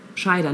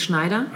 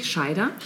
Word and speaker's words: Scheider: Mm, zu, diesen, zu ihren Schülern gehörten dann später erneut Marlon Brando, Roy Scheider, Scheider, nicht Schneider. Ne? Du weißt, äh, dass ich das ja mal falsch gesagt ja, habe in Der stimmt, Weiße Hai. – Richtig, ja. Scheider, ohne N.